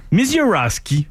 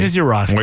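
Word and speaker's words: Misiorowski: Un autre nom qui est dur à prononcer. – Je l'ai mieux dit que je crois. – Ouais, tu l'as mieux dit, ouais. – Mizorowski. Ça se dit bien.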